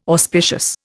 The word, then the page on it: auspicious: Indian